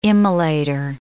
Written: immolator

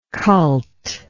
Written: cult

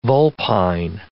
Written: vulpine

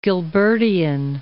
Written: Gilbertian

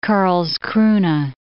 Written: Karlskrona

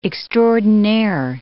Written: extraordinaire

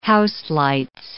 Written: houselights

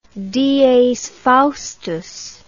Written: dies faustus

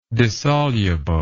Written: dissolubleness

